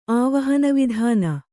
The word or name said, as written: ಆವಹನವಿಧಾನ